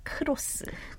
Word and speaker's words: 크로스: Because it is 크로스. – 크로스. – Yeah. – Three characters. – But it's actually cross. – Cross. – Which is one syllable.